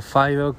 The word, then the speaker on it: FIDO: Fido o Fido, como Em, se pronuncia en inglés.